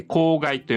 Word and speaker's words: 口蓋: 上顎のことなんですが、口の蓋と書いて口蓋と読みますね。口の中のあの天井です。天井と舌の距離が一番大きくなっている時にこのあの音が出ます。つまり大きく口を開けるっていうことは、当然舌の位置がこの天井、口蓋